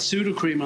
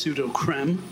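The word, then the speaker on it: Sudocrem: I've got pseudo cream on the list. Controversial, because people, it actually should be pronounced pseudo creme.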